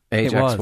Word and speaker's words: Ajax: Ajax was the toilet cleaner. – And that was how you pronounced that? – Yes, absolutely. Yeah.